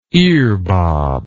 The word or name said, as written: earbob